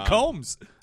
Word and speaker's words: Combs: But and Combs. And Combs is spelled correctly. – And Sean Jean. – There is the B in there. The B is silent, but it's in there.